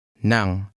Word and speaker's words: ng: Which part of that means of? – Nang. Nang.